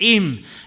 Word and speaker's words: Eyam: It's called Eam, E-Y-A-M, pronounced Eam.